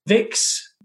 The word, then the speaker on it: Vicks: And Vicks in German is pronounced Vicks because of the, you know, V becomes an F.